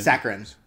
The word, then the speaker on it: saccharine: Looks a little saccharine, is that how you say it? – Saccharine, yeah. – Saccharine, thank you.